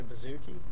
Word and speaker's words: bouzouki: I know you also play bouzouki.